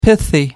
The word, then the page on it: pithy: American English